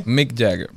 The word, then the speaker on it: Mick Jagger: Tiene Mike Jagger. O Jagger, ¿cómo se pronuncia? Mick Jagger. – ¿Eh? – Mick Jagger.